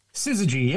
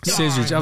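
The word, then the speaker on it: Syzygy: Scissorgy? – Scissorgy, yeah. – Scissorgy. Of course it is. Yeah, Scissorgy.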